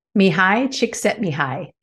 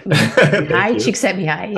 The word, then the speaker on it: Mihai Csikszentmihalyi: Mihai Csikszentmihalyi. – Yeah, okay. – Mihai Csikszentmihalyi.